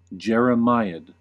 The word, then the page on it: jeremiad: American English